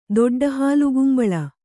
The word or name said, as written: ದೊಡ್ಡಹಾಲುಗುಂಬಳ